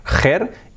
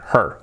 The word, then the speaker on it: her: La H, que no sea her, y la R, bien fuerte, her. Una sílaba, her.